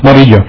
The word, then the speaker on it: Morillo: You can pronounce that for me. – That's enough. Morillo. – Okay. – I'm Javier Morillo, president of SEIU Local 26.